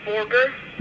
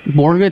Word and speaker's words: Borger: How do you say the name of the city? Borger. Borger, it's like a baby trying to say hamburger, huh?